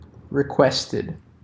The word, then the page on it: requested: American English